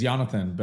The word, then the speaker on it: Yonatan: The full name is Yonatan, but Yon is perfect and much, much easier.